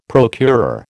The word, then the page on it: procurer: American English